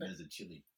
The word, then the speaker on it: Mazzucchelli: Mazzucchelli.